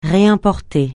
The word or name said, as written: réimporter